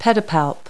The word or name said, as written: pedipalp